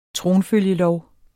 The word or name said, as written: tronfølgelov